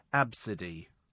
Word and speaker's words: Abcde: I mean, come on, A, B, C, D, E. That's how it's spelled, Absidy. That's how it's pronounced.